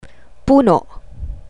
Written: puno

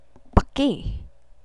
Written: paki

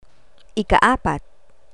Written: ika-apat

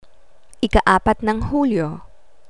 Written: ikaapat ng hulyo

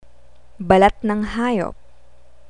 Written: balat ng hayop

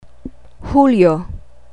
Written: hulyo

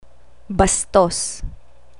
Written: bastos

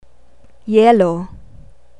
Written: yelo

